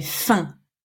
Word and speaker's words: faim: On dit « j'ai faim ». Faim comme 1, 2, 3. D'accord ? Tu ne prononces pas le « m » à la fin. Sinon, ça fait « femme ».